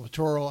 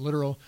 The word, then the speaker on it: littoral: I know some people call it littoral. I, I pronounce it literal.